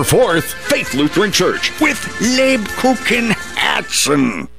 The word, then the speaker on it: Lebkuchenherzen: Lubkuchen Herzen. – The Kupenhergens Herzen. – Lubkuchen Herzen. – Nine! Lebkuchenherzen!